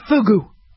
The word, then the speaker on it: fugu: Am I even pronouncing it right, or is it fuju? Let's just call it fugu. Make sure the U is long sounding, not the short U, okay?